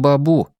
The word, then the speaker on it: бабу: Деда он с раннего детства называл папой, а вот бабушку отчего-то звал не мамой, а этим странным укороченным бабу с ударением на последнем слоге.